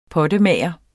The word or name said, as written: pottemager